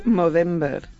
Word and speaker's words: Movember: Hoy vamos a hablar de Movember. Sí, lo he pronunciado bien, aunque tenga la voz un poco... – O sea, no es no, sino mo.